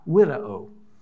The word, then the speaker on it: video: That word regard in the Latin language is spelled video, but it's not pronounced like that. It's pronounced widow.